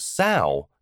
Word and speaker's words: sow: So is used here as a verb meaning to plant or spread, but a sow, same spelling, different pronunciation, is a noun meaning female pig.